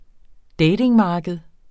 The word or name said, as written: datingmarked